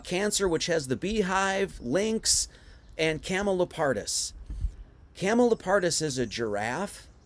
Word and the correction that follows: Camelopardalis: So, Camelopardalis. I keep saying this incorrectly, so I apologize. Camelopardalis.